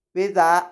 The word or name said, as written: bedaa